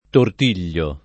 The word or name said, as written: tortigliare